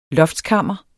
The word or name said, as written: loftskammer